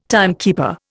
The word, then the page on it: timekeeper: Indian